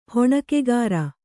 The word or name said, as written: ಹೊಣಕೆಗಾರ